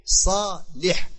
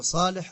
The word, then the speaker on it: Salih: On a le prénom Salah. Mais lui, son père, il s'appelle Salih. Salih. C'est important de bien prononcer les prénoms. Donc, Muhammad ibn Salih al-Uthaymin, rahimahullahu ta'ala, al-usul min ilm al-usul.